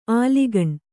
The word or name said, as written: ಆಲಿಗಣ್